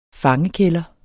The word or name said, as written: fangekælder